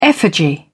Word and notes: effigy: British English

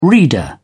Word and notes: reader: British English

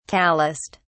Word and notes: callused: British English

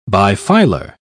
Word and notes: bifilar: British English

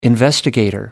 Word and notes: investigator: American English

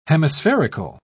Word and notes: hemispherical: British English

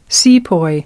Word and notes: sepoy: American English

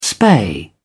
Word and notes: spay: British English

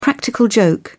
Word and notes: practical joke: British English

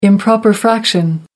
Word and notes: improper fraction: American English

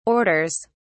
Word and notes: orders: British English

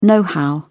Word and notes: know-how: British English

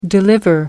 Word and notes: deliver: American English

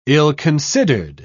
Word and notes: ill-considered: British English

